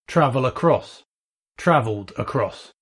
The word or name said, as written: travel across